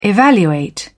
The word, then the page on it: evaluate: British English